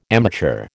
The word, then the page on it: amateur: American English